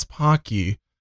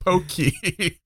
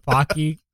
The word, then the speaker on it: Pocky: It's actually pronounced like Rocky, except with a P. And then he's like arguing with them. He's like, yeah, but I heard on TikTok that it was pronounced Pokey.